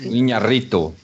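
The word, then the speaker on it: Iñárritu: Como é que fala? O Inharrito? – Inharrito. – Alejandro Inharrito. Isso aí.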